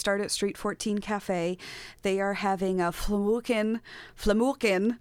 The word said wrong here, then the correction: Flammkuchen: Misha would be mad at me. He taught me how to say this yesterday, but a Flammkuchen Friday is starting at 5, and so that's a French-German pizza.